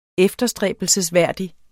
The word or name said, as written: efterstræbelsesværdig